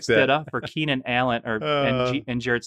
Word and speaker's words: Stidham: You don't like to pronounce the H, do you? Stidham. – Stidham. Like, why do you do that? Why? Just say Stidham.